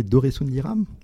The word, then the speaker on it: Doressoundiram: Doresundiram En plus, c'est bien prononcé, bravo Et donc, euh, cet astéroïde, il y en a plein dans le système solaire.